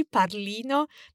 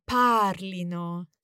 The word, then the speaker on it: parlino: Yes, and we need to watch the accent there, because we don't want to emphasize the ending, ino. Probably the temptation is to say it wrongly, parlino, but it's actually the emphasis on the stem, parlino.